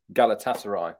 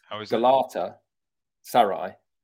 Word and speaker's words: Galatasaray: And I set myself up there for a bit of trivia about Galatasaray, but that's not actually how you say it. – Right. – Galatasaray. Galata is the place. And interestingly for Zaha, Saray is Turkish for palace. So he's gone from palace to palace. Galatasaray. Oh, but we say Galatasaray in England. And when I went to Turkey, they told me off.